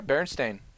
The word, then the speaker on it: Berenstain: I've heard Steen and Stain. – I think I heard Stein a couple times, like Frankenstein.